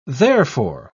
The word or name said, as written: therefore